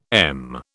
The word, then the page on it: em: British English